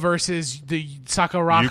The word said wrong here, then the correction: Sakazaki: Yuka Sakazaki. – Sakazaki. – I'm actually super happy I said that right.